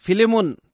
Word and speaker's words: फिलेमोन: फिलेमोन त्यहाँ पछाडि कसैले यसलाई यही भनेको थियो जुन मलाई लाग्छ वास्तवमै यो नै ठिक उच्चारण हो And that's what I want to use tonight as a text, the Lord willing, is a prisoner.